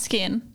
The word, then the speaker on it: SKKN: Is it SKKN or is it Skin? – No, it's pronounced Skin.